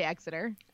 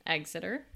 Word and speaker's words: Exeter: I would have said, I would say Exeter. – Exeter.